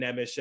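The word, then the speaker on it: Nemec: But looking at Simon Nemish, and uh, there, there's different ways to say his name. Uh, I, I've been hearing Simone uh, Nesmith or something like that. I, I have a buddy who, uh, who who's big on pronunciation for uh, European players, and he knows how to do it uh, correctly. But uh, the English version is uh, Simon Nemish, uh, as far as I know.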